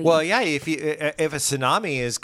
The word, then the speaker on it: tsunami: It's spelt T-S-U-N-A-M-I, but the T is silent. Tsunami. It's a Japanese word, which I think means big wave or something like that.